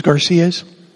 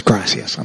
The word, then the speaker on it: gracias: Muchas Garcias? No. Of course, it's gracias.